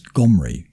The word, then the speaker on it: Omri: So the Assyrian pronunciation of it was Gomri, because they put a G-H before the O. Or that was the original way it was pronounced.